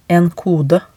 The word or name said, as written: en kode